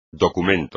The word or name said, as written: documento